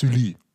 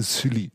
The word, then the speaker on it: Sully: Jardin de l'Hôtel Sullier. Ja, also ein Garten des Hotels Sully, so sagt man es. Jardin de l'Hôtel Sully.